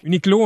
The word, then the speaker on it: Uniqlo: Uniqlo, on prononce pas le U, OK.